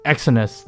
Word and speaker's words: Exynos: 最后一个新闻呢，跟大家分享的是 Samsung，Samsung 呢它的。这是怎么念啊？E X Y N O S Exynos 二二零的晶片哦，也就是他们的 SoC 晶片。